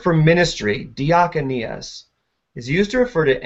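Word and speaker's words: Diakonias (διακονίας): That word ministry in the Greek is the word diakonias. Sorry, let me say that right.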